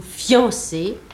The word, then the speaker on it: fiancé: Alors, il est évident que la prononciation correcte dans la conversation, dans la lecture, je n'envisage que cela pour le moment, hein. Prononciation et lecture, conversation et lecture, c'est fiancé, c'est-à-dire deux syllabes. Donc, il ne faut pas prononcer un i, mais on prononce un iode, fian, une seule syllabe, voyons.